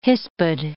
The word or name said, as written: hispid